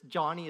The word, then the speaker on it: Joni: It's Johnny is a woman, okay? Her name is spelled J-O-N-I, but it's pronounced Johnny.